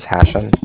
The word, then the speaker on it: Hashem: So, my name is Hashem. That's H-A-S-H-E-M, as in mother at the end.